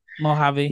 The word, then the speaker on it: Mojave: Mojave, Mayafi like King. The Mojave Desert Mojave. Here.